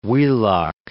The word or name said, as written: Wheelock